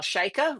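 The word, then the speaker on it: Chayka: So we're going to talk a little bit about Kyle Shaikha, rhymes with Micah's book, Filter World, which digs into how algorithms have flattened culture, shaping not just what we see, but how we experience media.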